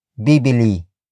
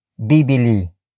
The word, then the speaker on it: bibili: that is bibili. Bibili. Bibili. Bibili is spelled as B-I-B-I-L-I.